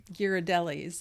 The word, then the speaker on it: Ghirardelli: This is this is not homemade hot chocolate, but it is the Ghirardelli's hot chocolate, so it should be pretty tasty.